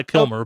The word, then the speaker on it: Kilmour: Pronounced Nicolai Kilmer, oh. but spelled Kilmour with only oh, one L.